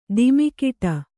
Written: ಧಿಮ್ಮಿಕಿಟ

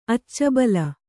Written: ಅಚ್ಚುಬೆಲ್ಲ

